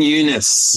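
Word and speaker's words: Younes: Lincoln, is it Yunes or Eunice? – Lincoln Eunice. Eunice, okay. Pronouncing his name wrong, yeah.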